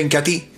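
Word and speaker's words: Inca Tea: Se escribe Incatea, pero se pronuncia Incatí.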